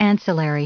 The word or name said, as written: ancillary